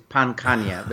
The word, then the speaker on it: Pankhania: It's Pankhania, but you did well.